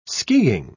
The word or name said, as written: skiing